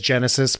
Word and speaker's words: Genisys: I mean, it is Genesis, but it is spelled super.